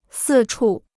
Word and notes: 四处: sì chù

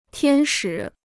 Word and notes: 天使: tiān shǐ

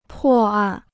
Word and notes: 破案: pò àn

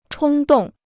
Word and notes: 冲动: chōng dòng

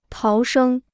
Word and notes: 逃生: táo shēng

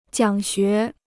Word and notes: 讲学: jiǎng xué

